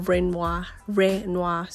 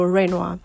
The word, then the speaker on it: Rhenua: I don't know why, but Rhenua. So like you want to pronounce Rema, but instead of the M, you insert N-U. So Rhenua.